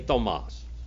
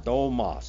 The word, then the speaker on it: Thomas: Thomas, say Thomas. Thomas. See, it's a long O. Thomas.